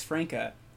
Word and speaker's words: Frnka: I'm here in studio with the one, the only, the Alex Frnka.